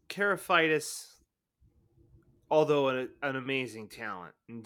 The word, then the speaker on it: Karlaftis: George Karlaftis from Purdue. – Really? Yes. George Karlaftis? – There it is.